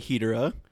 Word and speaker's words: Hedorah: Smog Monster, or Godzilla vs. Hedorah, or as they pronounce it in the movie, Hedorah.